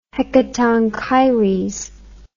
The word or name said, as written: Hecatonchires